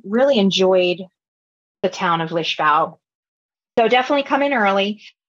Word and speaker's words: Lisboa: So for us, it was in Lisbon, Portugal, which they pronounce Lisbão. I kept seeing, you know, for us, it's spelled Lisbon. And I kept seeing Lisboa. And I thought, what's going on here? But that's how they say it.